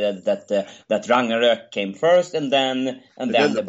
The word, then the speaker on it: Ragnarök: Yes, Ragnarök, that is how you pronounce it in Swedish.